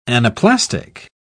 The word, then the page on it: anaplastic: British English